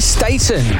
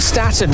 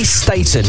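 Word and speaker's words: Staton: And it's Candy Staten, not Staten. We've been pronouncing her name wrong since the 70s. She put the record straight a couple of years ago on This Morning. It's pronounced Candy Staten, and that is Suspicious Minds.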